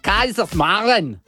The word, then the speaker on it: Kaiserslautern: Si pronuncia Kaiserslautern.